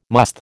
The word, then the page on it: musth: American English